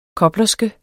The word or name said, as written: koblerske